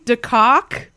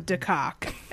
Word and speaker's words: De Cock: It's pronounced Deco, and it's like, it's all on board.